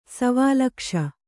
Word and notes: ಸವಾಲಕ್ಷ: savālakṣa

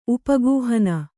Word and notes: ಉಪಗೂಹನ: upa gūhana

